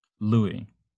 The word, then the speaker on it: Louis: Louis. – Yeah, like Armstrong.